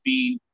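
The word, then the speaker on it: Biehn: Is it Bean or Bane? – I've always heard it pronounced Bean.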